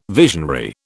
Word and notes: visionary: British English

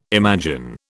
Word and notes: imagine: British English

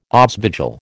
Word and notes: aasvogel: American English

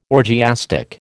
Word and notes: orgiastic: American English

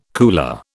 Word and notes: cooler: British English

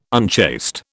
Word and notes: unchaste: British English

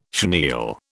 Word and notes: chenille: British English

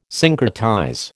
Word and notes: syncretize: American English

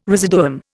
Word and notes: residuum: Indian

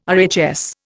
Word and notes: RHS: Indian

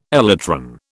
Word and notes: elytron: British English

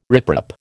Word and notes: riprap: American English

